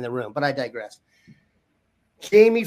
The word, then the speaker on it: Jaime: Alpha Dog named Jamie Ffrench. Not spelled like you'd think. Jamie, in fact, FF, it's actually J-A-I-M-E, FF. R-E-N-C-H.